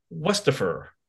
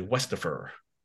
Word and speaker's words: Westafer: And for the first time joining us on the show, we have Dr. Lauren Westafer. Am I saying it correctly? Westafer.